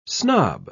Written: snob

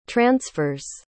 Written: transfers

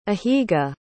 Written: ahiga

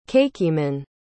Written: kakiemon

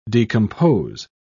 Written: decompose

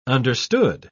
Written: understood

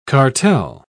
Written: cartel

